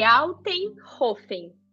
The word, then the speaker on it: Altenhofen: Mas eu posso, eu posso corrigir. Altenhofen.